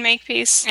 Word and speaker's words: Makepeace: You can search my name, which is Christine Makepeace. – And it is spelled exactly how it sounds.